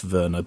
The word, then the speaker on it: Werner: It's pronounced Verna, but spelt Werner, you see, European.